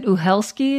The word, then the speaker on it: Uhelszki: And I, I I think Jaan is it Uhelszki? Is that how you say her name? – Was yeah.